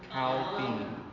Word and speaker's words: caubeen: Cow bean. C-A-U-B-E-E-N. Cow bean. – Cow bean. – Like C-O-W-B-E-A-N. – C-A-U, cow, bean.